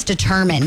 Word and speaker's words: determine: I was like, Dietermine. And my teacher's like, no, honey, that's determine.